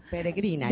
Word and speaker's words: Peregrina: Now, one of the next songs you're going to be performing for us, and I'm sorry if I slaughter the name, Peregrina. Am I saying it right? – That's pretty good. Peregrina.